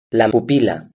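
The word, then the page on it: la pupila: Spanish (Spain)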